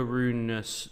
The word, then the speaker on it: Rune: Interesting, the Holger Rune, have to pronounce the E, we now know this, stuff.